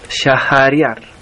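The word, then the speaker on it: Shahriar: Aquí las H se pronuncia como una J, Shahariar y Shaezeman.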